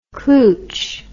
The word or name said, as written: klooch